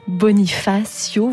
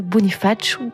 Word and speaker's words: Bonifacio: Et puis, pardon à vous si vous êtes corse comme Thomas Cligny, je n'ai pas prononcé comme il le fallait la ville de Bonifacio. Voilà, à la française, Bonifacio pour la version corse.